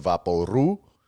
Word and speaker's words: vaporú: a thing we called vaporú, which is how you say vapo rub in Puerto Rican Spanish.